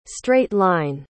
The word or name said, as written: straight-line